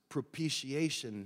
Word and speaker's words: propitiation: Pastor, I love you, and I want people to think the best of you, so I need to tell you the word is pronounced propitiation, not propitiation.